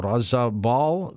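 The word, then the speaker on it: Rozabal: Her books include Jesus in Kashmir and also Razabal, the Tomb of Jesus. Did I pronounce that right, Suzanne? – Yes, you did. You're spot on.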